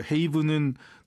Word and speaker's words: haven: 그래서 haven은 또 haven대로 발음하실 줄 알아야 돼요.